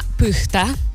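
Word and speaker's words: Pyhtää: Perché, per esempio, se noi andassimo a Pyhtä, questa è la pronuncia corretta,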